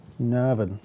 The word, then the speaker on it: Navan: I always get in trouble when I say Navan. – That's not how you say it. – No, come on. How'd you say it? – Navan.